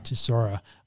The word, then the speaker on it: Chisora: Chisora.